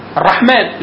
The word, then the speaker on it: الرحمن: الرحمن بعد الميم في ألف صحيح ما كتبهاش كده ليه الرسم المصحفي كده بس تقبل ان هي تتنطق ايه الرحمن ليه لانه ده اشباح حرف.